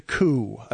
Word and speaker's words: coup: I believe it's a coup. I believe it's spelled with a P, but that the P is silent there. So it's not a coup.